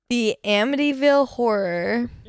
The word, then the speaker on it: The Amityville Horror: I don't actually know how to say this. I know how to say it in theory, but I know I'm going to try to say it, and I'm not going to be able to. I know you know how to say it. The Amityville Horror. – Yeah, you said it right.